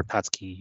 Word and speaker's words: Tatsuki: But Tatsuko, you kind of go, or Tatsuki, you kind of go over the U like in Asuka.